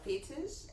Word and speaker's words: fetish: That almost sounds like you're saying fetus. – No, fetish.